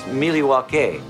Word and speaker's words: Milwaukee: Fox News is hosting tonight in Milwaukee. – Actually, it's pronounced Miliwake, which is Algonquin for the good land.